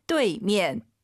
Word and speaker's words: 对面: UI 사이에 에너야죠. 뚜이면, 뚜이면. 선생님 알아듣죠? – 能听懂. – 네, 안 예뻐요. 다시 한번. – 对面. – 뚜에이, 对面. 빠르게 발음하시면 뚜이로 들리긴 하죠. 对, 정확히 발음하셔야 됩니다.